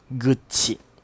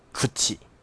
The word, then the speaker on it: 口: 他们日文的一些念法，因为我以前不知道的时候，我都是念 Yamakuchi，但是呢，因为日本的日文的嘴巴，它的口嘛，就是念 Kuchi，但是它用成姓的时候，它就是会念成 Guchi，然后我那时候每次都念 Kuchi，呃，然后一开始还跟他们不是很熟的时候。